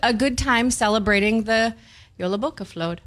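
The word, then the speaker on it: Jólabókaflóð: Yola Boca Float. – It sounds like it starts with a Y, but it's a J.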